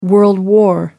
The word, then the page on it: world war: American English